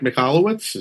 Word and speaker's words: Michalowicz: You know, the book by I'm going to butcher his last name, Mike Michalowicz.